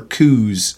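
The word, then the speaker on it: cu: or coos. The letter U is pronounced in Portuguese as you pronounce ooh in English.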